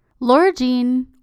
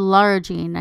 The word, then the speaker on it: Lara Jean: Laura Jean, or Lara Jean, I guess is the right way to say it, although I always said it Laura Jean in my head, She's the middle child and she's always had a crush on Josh.